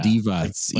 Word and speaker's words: Divac: Divac, Divac. – I think it's Divots. Yeah. – Divots. Like yeah.